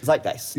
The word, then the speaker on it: Zeitgeist: How am I saying that wrong? – Zeitgeist. – Yes, thank you.